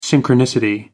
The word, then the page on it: synchronicity: American English